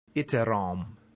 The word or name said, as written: iterum